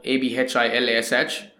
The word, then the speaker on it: Abhilash: so my name is spelled A B H I L A S H.